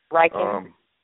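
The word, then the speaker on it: Reichen: Riken.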